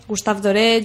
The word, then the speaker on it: Gustave Doré: Y luego eh, ilustradores del siglo XIX como Gustavo Doré, Gustave Doré,